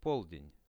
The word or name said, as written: polden